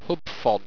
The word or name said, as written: hubphon